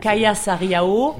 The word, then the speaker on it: Kaija Saariaho: Kaija Saariaho.